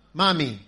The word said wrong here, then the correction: Mwami: Mwami. – Mwami. – Mwami. – Mwami. – Yeah.